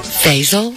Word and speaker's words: Faisal: How would you say that last name? – Faisal. – Faisal? – Huh? – Like Faisal Phillip? – Sure.